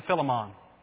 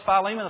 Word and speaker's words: Philemon: Or for those of you raised in the church I was raised in, Philemon. How many of y'all as a kid were taught to pronounce it Philemon? All right. I got the Bible college and learned it was Philemon.